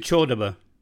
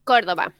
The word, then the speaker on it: Cordoba: She comes from, is it Cordoba, or do you pronounce it Chordoba? – Cordoba. – In Cordoba. Yeah. I got it.